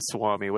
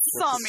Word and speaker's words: Sami: And then they flip over another page, and it's a Laplander, you know, a Swami, whatever they're called. – A Swami. Oh, I believe it's Sami. Sami. – Swami, yes. – Well, it's spelled S-U-O, right? – I think it's S-A-M-I or S-A-A-M-I.